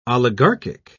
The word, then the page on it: oligarchic: British English